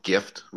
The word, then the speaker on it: Gyft: The earliest one of these that I remember was an app called Gift, which is spelled GYFT,